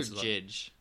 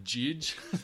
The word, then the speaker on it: Jydge: Is yeah, it Judge or Jidge? – Well, it's spelt like Jidge, like J-Y-D-G-E.